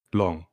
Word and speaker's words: Leung: And starring Simu Liu and Tony Leung. I said that wrong. – Long.